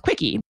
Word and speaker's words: CWCKI: Now, originally, the site was called CWCKI, an acronym for Christine Weston Chandler's name, pronounced as Quickie.